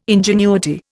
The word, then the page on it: ingenuity: Indian